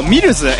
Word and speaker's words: MLZ: ちなみにこの人らは全部アーティストがアンノウンなんですけど、ミルズ MLZ って書いてミルズって読むらしいんですけど、それでダブテクノのアーティストをやってる連中で、マンチェスターのなんかこう激レア情報じゃん。